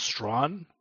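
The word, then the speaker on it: Strachan: I just learned apparently it's pronounced Strawn. I mean, you're Scottish. – Strawn, okay, yeah, that makes sense. He was Scottish after all, okay. – Can you confirm that? Is it Strawn?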